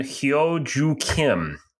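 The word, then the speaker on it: Hyo Joo Kim: And claiming the victory by four shots was the South Korean Hyo Ju Kim. Did I say that right? – Pretty good on the pronunciation.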